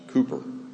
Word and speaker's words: Cowper: You'll notice his name is spelled C-O-W-P-E-R, and please know it is pronounced Cowper. Cowper.